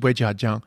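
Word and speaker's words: Weijia Jiang: I realized I was actually, I actually thought she was Wei Jiaxiong, but she's Wei Jiajiang. And when in previous episodes, I was mispronouncing her name.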